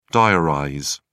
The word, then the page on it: diarize: British English